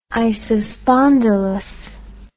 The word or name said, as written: isospondylous